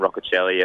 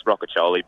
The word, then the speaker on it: Roccichioli: I think some of the commentators are saying Roccichioli. It's Roccichioli, but I'm pretty used to it.